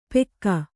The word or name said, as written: ಪೆಕ್ಕ